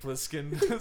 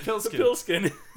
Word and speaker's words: Plissken: Snake Plissken. – Snake Pilskin.